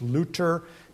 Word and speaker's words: Luther: A monk by the name of Martin Luther, if you go to Germany, no, you don't pronounce the H, Luther, nailed his 95 theses to the church door.